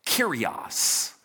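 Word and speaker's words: Kyrios (κύριος): The word that you usually say is pronounced like this Kyrios.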